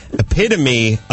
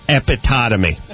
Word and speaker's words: epitome: epitome of a dumb blonde, although the first three times I looked at it, I pronounced it epitotomy.